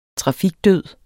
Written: trafikdød

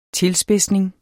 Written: tilspidsning

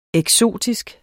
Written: eksotisk

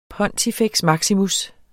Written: pontifex

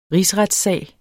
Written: rigsretssag

